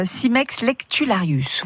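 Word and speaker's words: Cimex lectularius: Ouais, euh, Simex lectularius, voilà, c'est ça.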